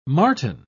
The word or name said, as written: marten